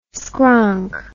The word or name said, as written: skronk